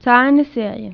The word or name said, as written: روزنامهء کوچک